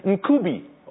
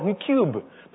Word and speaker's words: Ncube: N-C-U-B-E. Most people mispronounce and call it Ncube or Ncube. But his last name is Ncube.